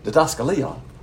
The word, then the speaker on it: didaskalia: That word is didaskalia, and that is pronounced correctly.